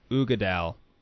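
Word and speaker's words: Uigeadail: But apparently it's pronounced Ugadal. – Or Ugadal. – Ugadal. I think it's Ugadal.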